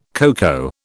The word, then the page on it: cocoa: British English